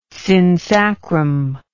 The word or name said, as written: synsacrum